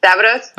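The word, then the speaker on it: Szabados: And then I always say her name wrong. Shannon Sabagados. – Sabados. – I never say her name right.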